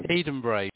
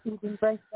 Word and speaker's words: Edinburgh: Edinburgh, but that's fine. – Edinburgh, that's good.